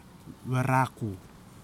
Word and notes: warakou: kwamera

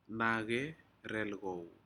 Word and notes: nace relcau: Aneityum

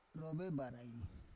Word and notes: rovebarai: Roon

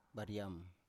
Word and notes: bariam: Biak

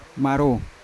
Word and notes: maro: Yawa

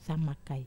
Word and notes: sama kai: wamesa

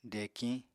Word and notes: dekiñ: apatani

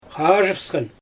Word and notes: xaaʒɯfsxan: Xyzyl

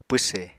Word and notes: pwse: Apatani

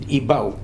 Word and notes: Ibau: new form of Pau

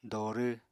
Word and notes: dorw: apatani

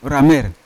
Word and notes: ramer: Yawa

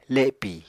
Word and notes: lepì: Apatani

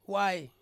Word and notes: wai: Ansus

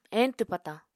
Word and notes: eñtw patañ: Apatani

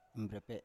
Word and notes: mbrepe7: Biak

